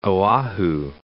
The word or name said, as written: Oahu